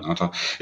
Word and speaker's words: Ngata: Da konnten sie irgendwie nicht so richtig gut auftreten, weil Sigi Ansah und Haloti Ngata, ich glaube, das spricht man Ngata. – Ngata. Ja, Haloti Ngata.